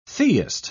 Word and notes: theist: British English

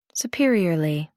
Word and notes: superiorly: American English

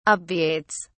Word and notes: obviates: British English

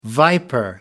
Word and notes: viper: American English

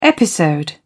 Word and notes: episode: British English

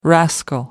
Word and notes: rascal: American English

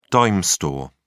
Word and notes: dime store: British English

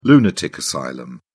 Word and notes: lunatic asylum: British English

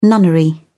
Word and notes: nunnery: British English

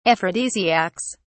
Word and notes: aphrodisiacs: British English

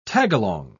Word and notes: tagalong: British English

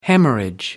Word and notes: haemorrhage: American English

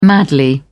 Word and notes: madly: British English

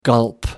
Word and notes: gulp: American English

